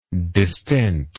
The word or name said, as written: distent